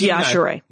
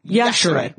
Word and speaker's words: Yashere: It's Yashere. Yashere. – Yeah. – Pronounce the E, people.